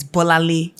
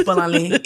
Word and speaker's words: Bolanle: So I remember, you know, recently I was like, when I go to America or when I'm in America, I'm never going to introduce myself as Bola. It is Bolanle.